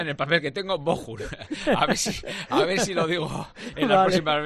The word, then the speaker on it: Bochum: Y siempre me ha dicho que es el Bochum, es el Bochum, como si pronuncias una J, Bochum.